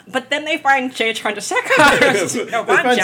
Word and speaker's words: Chandrasekhar: Director Jay Chandrasekhar. – Chandrasekhar, thank you.